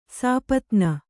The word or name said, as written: ಸಾಪತ್ನಿ